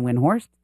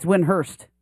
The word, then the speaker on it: Windhorst: Do you know that it's not actually Brian Windhorst? That's not how you say his name. – What is it? – It's Windhorst. Seriously.